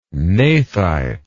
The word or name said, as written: gnathite